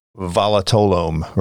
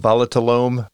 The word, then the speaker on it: volatilome: And I thought some of the more interesting stuff in the book had to do with what you call the volatilome, right? Is that how you say it? – Yeah. – The volatilome, and how dogs can smell whether someone has cancer.